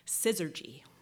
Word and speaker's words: syzygy: Syzygy.